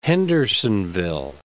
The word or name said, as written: Hendersonville